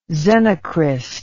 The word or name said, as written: xenocryst